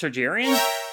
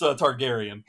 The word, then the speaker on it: Targaryen: House Targaryen? Did I get it wrong? – A little bit. – I have never heard anyone pronounce it with a soft G yet. That's interesting. No, okay. – It's Targaryen.